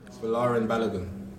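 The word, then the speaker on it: Folarin Balogun: Falarin Balogun. – Balogun. – Falarin Balogun. Vi kör det igen. – Had you right. Falarin Balogun. Timothy Weah. Mark. Falarin Balogun. – Alltså. – Had you right. Falarin Balogun.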